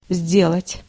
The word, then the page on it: faire: France; [fɛʁ]